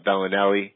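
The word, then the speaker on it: Belinelli: Last year, remember they signed Rondo, they signed Bellini, I'm mispronouncing the name. Bellinelli,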